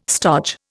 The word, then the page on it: stodge: Indian